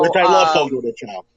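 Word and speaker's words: Fogo de Chão: Is it Fogo de Chao or Fogo de Chao? – Fogo de Chao. – Is it Chao? I heard cow. I hear some people.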